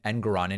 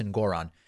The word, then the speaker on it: Engoron: Good for Judge Engoron, who I guess I've been pronouncing his last name Engoron incorrectly. Apparently it's Engoron.